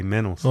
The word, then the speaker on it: Mentals: We don't pronounce the L though. Mentals. – No, you don't pronounce the T. Mentals.